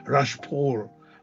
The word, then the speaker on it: Rachhpaul: My name is spelled Rashpal, R-A-C-H-H-P-A-U-L, and the miracle of my cure.